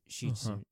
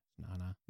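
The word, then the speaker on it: Nana (なな): Like in Japanese, shichi, you'll learn it shichi as 7, but nobody ever says that. – Nana. – Nana, which literally translates to nothing. And so you'll just say nana.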